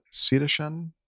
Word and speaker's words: cetacean: According to the Washington State Department of Transportation Aviation Division, the deceased, Cetacean? Do you know how to pronounce that? Anybody? – I've lost where you are in the... – I've highlighted it. I don't know if you can see my... – Cetacean. It's cetacean.